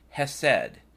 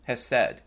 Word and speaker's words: Hesed: You know, last week in service, our minister talked about, and I want to pronounce this correctly, Hesed, and I believe that's in English spelled Hotel Echo Sierra Echo Delta. Yeah, Hesed.